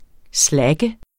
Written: slacker

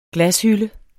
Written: glashylde

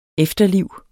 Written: efterliv

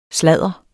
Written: sladder

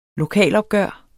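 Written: LOKALOPGØRET

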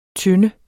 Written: tynde

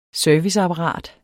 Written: serviceapparat

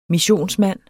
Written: missionsmand

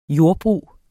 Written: JORDBRUGET

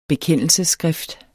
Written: bekendelsesskrift

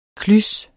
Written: klys

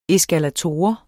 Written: eskalator

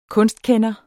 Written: kunstkender

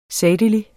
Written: sagtelig

